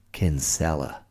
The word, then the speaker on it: Kinsella: Kinsella. But as Americans like to pronounce it, Kinsella.